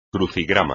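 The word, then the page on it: crucigrama: español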